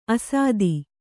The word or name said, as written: ಅಸ್ಥಿ